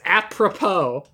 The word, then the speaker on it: apropos: You still said it wrong. – A pro-pro? – You said, it's apropos, not a pro-pro.